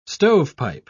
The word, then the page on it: stovepipe: British English